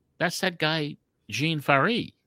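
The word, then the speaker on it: Ferré: Because he was the Giant Jean Farry. – Yes. I never pronounced that name right. I always called him John Fair, you know? – Yeah.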